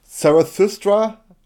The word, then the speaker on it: Zarathustra: It's Zarathustra. I don't know how you would pronounce it in English. Yeah, Zarathustra, exactly.